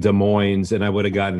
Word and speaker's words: Des Moines: Is Des Moines or is it just Des Moines? – Just Des Moines. – Okay. – It's not plural.